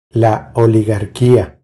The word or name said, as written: La oligarquia